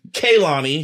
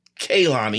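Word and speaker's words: Kehlani: Then Kaylani, because, she told me that's how you're supposed to say her name now. – Yep. – Kaylani. – That's how you've been supposed to say it. – I know. Mm-hmm. We've been saying Kalani this whole time.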